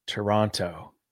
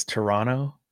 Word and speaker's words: Toronto: You can tell you're American because you pronounce the second T, Toronto. – I am what I am, brother. – the Canadian way to pronounce it is Toronto.